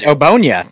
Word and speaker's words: Ogbonnia: You know it's, him better than I It's Obonia. It's just Obonia. It's nice and simple.